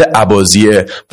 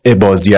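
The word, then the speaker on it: اباضیه: تلفظش در عمان به شکل عباسیه و در شمال آفریقا عبازی است که هر دوش درسته.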